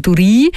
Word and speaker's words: Duri: Ich habe übrigens erst nach dem Gespräch erfahren von ihm, dass man seinen Namen eben anders ausspricht. Also nicht Duri, sondern Duri. Das klingt auch gerade so schön nach Engadin, oder?